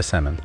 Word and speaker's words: salmon: Just say salmon. – Go to Oxford and you tell them that there's no L in salmon.